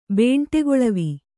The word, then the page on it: ಬೇಂಟೆಗೊಳವಿ: Kannada